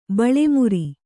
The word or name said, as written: ಬಳೆಮುರಿ